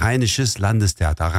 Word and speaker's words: Rheinisches Landestheater: Ardından benim için telaffuzu zor olan Rheinisch Landestheater, söyler misiniz? – Evet, Rheinisches Landestheater.